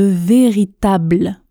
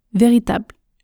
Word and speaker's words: véritable: Ensuite, quand je parle vite, je ne prononce pas le L de véritable ou très très légèrement. Écoutez, véritable, véritable, véritable.